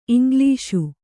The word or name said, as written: ಇಂಗ್ಲೀಷು